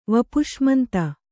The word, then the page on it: ವಪುಷ್ಮಂತ: Kannada